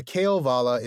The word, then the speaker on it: Kalevala: So the emphasis is on the first. – Yeah. I think I've always said Kalevala in the past. – Yeah. I had too. – And I started hearing Kalevala. – Okay. – Which is very subtle. I think it's just, is it the first syllable or the third syllable that is more pronounced, but right.